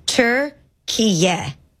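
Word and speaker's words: Türkiye: Okay, the official pronunciation of the country's recognized name is Turkiye. – Playing Turkey then. – You weren't that far off. – T-U-R.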